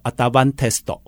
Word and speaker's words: Advantest: 哦，日文的 Advantest 叫做 Advantesto。